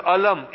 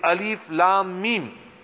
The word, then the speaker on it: Alif Lam Mim: He actually uttered the word, no, it's not pronounced as Alam. It is Alif Lam Mim.